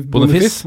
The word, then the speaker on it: Boniface: Boniface, Boniface, ja.